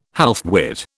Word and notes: halfwit: British English

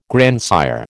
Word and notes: grandsire: American English

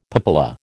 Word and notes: papilla: American English

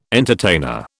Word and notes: entertainer: British English

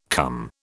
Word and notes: come: British English

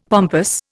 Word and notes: pompous: Indian

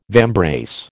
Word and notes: vambrace: American English

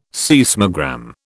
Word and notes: seismogram: British English

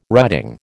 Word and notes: writing: American English

